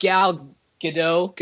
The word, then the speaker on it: Gal Gadot: I like Gal Gadot. Am I saying that right? – Yeah.